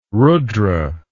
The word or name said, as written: rudra